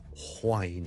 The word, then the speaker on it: whine: 其实如果你学某一啲 accent 咧，系的确咧系会读 whine 咁样嘅。例如爱尔兰嘅英文咧，好多时候咧都会听到。 Wine W-I-N-E 同埋 W-H-I-N-E 发音系唔同嘅，听住第一个系 wine wine wine wine wine，第二个系 wine wine wine wine wine，听落去你嘅感觉咧，当佢好似 H 音之前跟住先加个 wine 咁，wine and wine，咁就唔系错唔错嘅问题，而系唔同地方嘅口音。